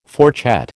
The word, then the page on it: fourchette: American English